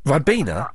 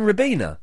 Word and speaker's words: Ribena: Well, but you love it so much, but you're saying it wrong. – I'm saying it wrong. – You're saying Ribena. – No, Ribena. I'm a Ribena boy.